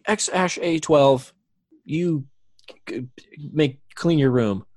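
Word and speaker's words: X Æ A-12: So how you pronounce the name is X and then the AE is pronounced Ash. And then A-12 is just A-12. – X-A-12.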